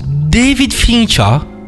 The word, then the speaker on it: David Fincher: David Fincher.